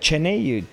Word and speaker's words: Cheney: Cheney.